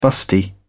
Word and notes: busty: British English

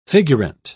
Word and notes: figurant: British English

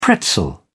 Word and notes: pretzel: British English

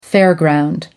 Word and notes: fairground: American English

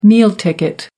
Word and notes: meal ticket: American English

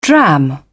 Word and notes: dram: British English

